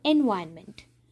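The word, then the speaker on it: environment: இதை நம்ம வந்து என்விரான்மெண்ட் அப்படின்னு வந்து இத்தனை வருஷமாக உச்சரிச்சுட்டு இருப்போம். ஒருவேளை நீங்களும் அதே தப்பு பண்ணியிருந்தீங்கன்னா இன்றைக்கி இந்த விஷயத்தை கற்றுக்கோங்க. இது என்விரான்மெண்ட் இல்லை என்வாயன்மெண்ட்.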